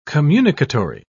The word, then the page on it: communicatory: British English